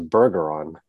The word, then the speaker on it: Bergeron: So it's like, it's Bergeron, like badger. It's like saying badger, but it's Bergeron. You can say Bergeron, it's totally fine. But if you're in Louisiana, that's how you say it.